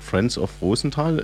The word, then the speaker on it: Friends of Rosenthal: Also ich habe es mir so gemerkt, Friends of Rosenthal, ist das alles richtig so? Das ist sehr korrekt, ja.